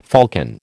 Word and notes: falcon: American English

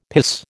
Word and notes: piss: American English

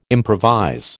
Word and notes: improvise: American English

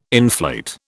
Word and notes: inflate: British English

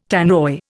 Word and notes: Tannoy: Indian

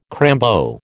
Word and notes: crambo: American English

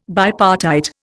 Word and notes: bipartite: Indian

